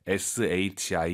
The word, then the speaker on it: 时: 이 거 时 입 니다 아니 에요 뭐 뭐 할 때 SHI 이 성이 에요